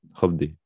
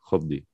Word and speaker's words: Hovvdy: Hopdi. ¿Cómo se escribe? H-O-V-V-D-Y. Hopdi.